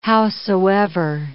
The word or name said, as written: howsoever